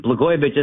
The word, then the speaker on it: Blagojevich: Before I do that, let me just explain that John Katsimatidis, Blagojevich isn't hard to say. It rhymes with Katsimatidis.